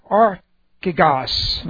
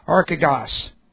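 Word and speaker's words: Archigos (ἀρχηγός): That's A-R-C-H, long E-G-O-S. Okay. Archigos. It's made up, it's a compound word. It's made up of arche, A-R-C-H, long E, arche, which has different concepts. It means first. It means first. It means the beginning. Plus, ago, A-G, long O, and that means to go ahead of, to lead. So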